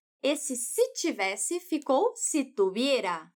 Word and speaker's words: tuviera: Esse verbo conjugado assim, Tuviera se escreve com V e a Juana fala esse V com o som de B do espanhol, aquele mais sutil porque a letra V está entre vogais. Estuviera.